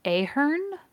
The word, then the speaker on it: Ahern: She often used the pseudonym Bianca Ahern. I believe that's how you say it. It's A-H-E-R-N.